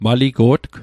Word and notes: maligo'tg: Mi'gmaq

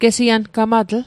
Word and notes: gesiangamatl: Mi'gmaq

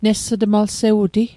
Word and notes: nesutmalsewu'ti: Mi'gmaq

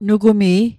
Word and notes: nugumi: Mi'gmaq

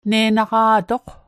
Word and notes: ne'naqa'toq: Mi'gmaq

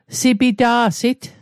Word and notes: sipita'sit: Mi'gmaq

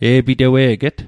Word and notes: e'pitewe'get: Mi'gmaq

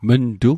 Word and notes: mntu: Mi'gmaq

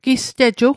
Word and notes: gisteju: Mi'gmaq

